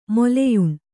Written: ಮೊಲೆಯುಣ್ಣು

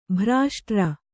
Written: ಭ್ರಾಷ್ಟ್ರ

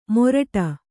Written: ಮೋರಟ

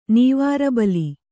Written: ನೀವಾರಬಲಿ

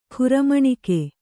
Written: ಕ್ರಮೇಣಕ

